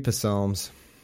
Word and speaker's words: Psalms: So we're gonna read three Psalms. Oh, I know, so funny. I pronounce the P.